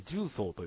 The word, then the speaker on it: 十三: 13 と書いて重曹と読む。